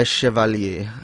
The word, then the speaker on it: Chevalier: Chevalier is the correct pronunciation.